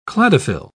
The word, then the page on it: cladophyll: British English